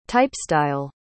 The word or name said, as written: typestyle